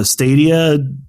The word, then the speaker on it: Stadia: Stadia system.